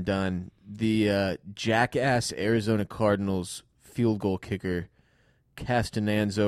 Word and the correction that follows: Catanzaro: or whatever. – Catanzaro. – He doesn't deserve to have his name pronounced correctly ever again.